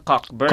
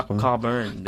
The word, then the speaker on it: Cockburn: Pero, yung pronunciation niya, ay, pag binasa mo siya, parang Cockburn. – Cockburn, yes. – Oo, mm-hmm. pala Coburn.